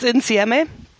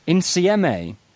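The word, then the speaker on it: insieme: How would you... Go on, say it again. – It's NCMEA. – NCMEA. – Yeah, it means all together, I believe, in Italian.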